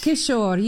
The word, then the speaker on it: Kishore: It's pronounced Kishore. You don't need to put it? – The E on the end.